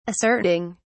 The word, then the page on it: asserting: British English